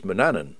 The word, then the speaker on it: Manannan: And the old words from about 1500 include the name as both Manannan and Manannan. The usual pronunciation these days is Manannan.